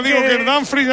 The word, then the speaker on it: Dumfries: Lo digo bien, Miguelito, Dumfries. – Tengo dudas en esa ¿cómo es exactamente? – Yo creo que es Dumfries.